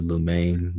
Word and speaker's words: Lumene: Renee Lumain. Name is spelled R-E-N-E. Last name L-U-M-E-N-E.